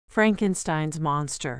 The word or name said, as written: Frankenstein's monster